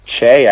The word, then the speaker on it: Che: It's Che, actually, but Che. I pronounced it Choi for like the first year and a half until I got this soul and somebody laughed me out of a building.